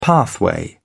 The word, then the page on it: pathway: British English